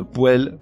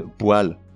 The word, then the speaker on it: poêle: On ne dit pas la moelle, mais la moelle, ou un ou une poêle, mais un ou une poêle,